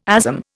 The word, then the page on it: ASM: Indian